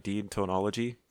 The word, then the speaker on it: deontology: Deontology? – Deontology.